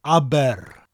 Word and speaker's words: Aber: Spelt A-B-E-R, Aber, with that rolling R sound, and Tawe, T-A-W-E. Abertawe, which means mouth of the river Tawi. Now Aber is pronounced like the name of the Swedish pop group, ABBA, but with that rolling R sound at the end of the word. Aber.